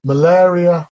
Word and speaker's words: malaria: Malaria, pronounced malaria. One way of remembering it is the malaria scare. Malaria scare. Air is the sound there.